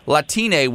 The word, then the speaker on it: Latine: And they're comparing Latine, which, if you're an English speaking person, it's spelled L A T I N E. Mm-hmm. So it looks like mm-hmm. Latin, right? That's how an American might see that.